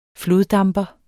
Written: floddamper

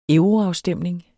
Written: euroafstemning

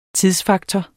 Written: tidsfaktor

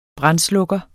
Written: brandslukker

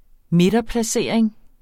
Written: midterplacering

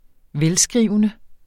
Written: velskrivende

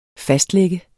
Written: fastlagte

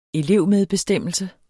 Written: elevmedbestemmelse

